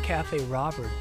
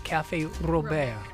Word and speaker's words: Café Robert: I bet you people mispronounce it. I bet they call it Cafe Robert, but it's actually pronounced Cafe Robert.